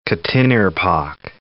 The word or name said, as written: Quttinirpaaq National Park